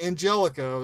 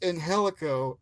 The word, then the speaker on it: Angelico: Not Angelico. That's if you're stupid. It's pronounced Angelico and Jack Evans.